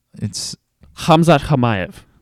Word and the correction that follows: Chimaev: Hamzat Hamayev. Hamzat Chimaev, I guess. I think everyone pronounces the CH. Hamzat Chimaev. Bingo. Let's make sure we have that right. It's Chimaev.